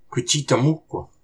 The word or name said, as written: Qucitamoq